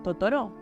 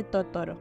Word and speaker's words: Totoro: Es porque en japonés se pronuncia Tororu. Por eso es que Mei le dice Totoro.